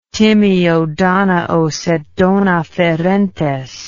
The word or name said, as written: timeo danaos et dona ferentes